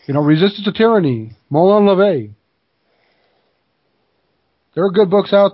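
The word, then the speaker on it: Molon labe: Molon Lob. Molon LeVay, actually, is how it's pronounced. Molon LeVay. LeVay, depending on which version of Latin you speak, the Old Latin or New Latin.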